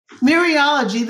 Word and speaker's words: Mereology: Mereology, is that how you pronounce that? – Mereology, that's again the whole parts whole.